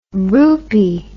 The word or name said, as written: roopy